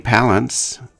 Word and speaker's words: Palance: And then Holly Palance, rhymes with balance,